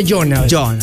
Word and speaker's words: Jonald: ¿Cómo se escribe Jonal? Porque muchos van a decir Jonal con E de Y o yo. – J-O-N-A-L-D, Jonald.